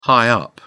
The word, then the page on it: high-up: British English